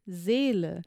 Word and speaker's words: Seele: Well, the first one is Seele, which is the German word for soul, and Seele is kind of close,